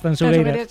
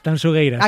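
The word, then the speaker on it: Tanxugueiras: Tansugueiras. – Sí, esto me lo corrige Fermín Agustín mucho. Marta, por favor, digo, mira que yo siempre pronuncio... A ver, otra vez, dímelo. – Tansugueiras.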